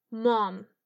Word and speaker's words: mom: La primera opción es mom. Mom. El O en mom es abierto y un poco largo.